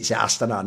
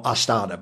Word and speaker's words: Astana: And then there's PGL Astana, I think, which is actually how you say it, Astana, not Astana, but whatever.